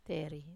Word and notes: teri: Wamesa

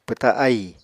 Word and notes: pwta ahì: Apatani

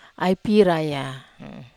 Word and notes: ai pira: Wamesa

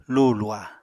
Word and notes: luhu-luha: apatani

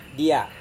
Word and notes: dia: Serewen

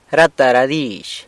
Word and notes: rata ra dizh: sanlucasquiavini

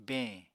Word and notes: beñ: Apatani

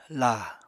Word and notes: la: Apatani

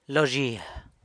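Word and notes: lo zhia: San Lucas Quiaviní Zapotec

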